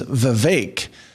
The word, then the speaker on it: Vivek: But the actual pronunciation is Vivek. It rhymes with cake, as our guest has explained.